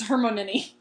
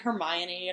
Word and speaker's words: Hermione: And she says, Hermione. – Yes. – And then I'm pretty sure the whole world rejoiced because we finally know how to say Herman's name. – Yes. Because I think Krum never gets it. He says Hermonini.